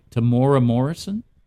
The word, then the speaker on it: Temuera Morrison: And it is, I never pronounce his name right, Temuera Morrison? – Yep. – I'm going to say that's how it's pronounced.